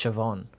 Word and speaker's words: Siobhan: Like you look at it and it says Siobhan, but it's actually pronounced Siobhan.